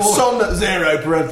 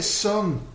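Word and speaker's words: Sunn O))): That is the twattiest pronunciation of that name I've ever heard. Sun, Zero, How else do you communicate that? – You just pronounce it Sun.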